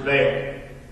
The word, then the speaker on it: Baal: Jezebel has introduced the people to the worship of a nature god called Baal. Some people pronounce it Baal.